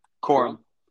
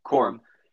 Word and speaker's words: Corum: Corum. – Corum.